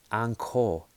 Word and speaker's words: Angkor: So Angkor, A-N-G-K-O-R, it's actually pronounced Angkor, Angkor, Angkor, Angkor. That's how they pronounce it.